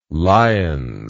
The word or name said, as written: lions